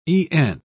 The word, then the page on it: e'en: British English